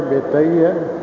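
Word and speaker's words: ਬੇਤਾ: इहदा तां बब्बे नाल आ, बब्बे नूं लां लगी है, तुत्ते नूं कन्ना लग्गिया, बेता ही है, होर तां कोई उच्चारण हो नहीं सकदा।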